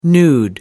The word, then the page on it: nude: American English